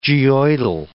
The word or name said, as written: geoidal